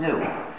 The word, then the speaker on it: GNU: Well, according to the dictionary, it's pronounced new.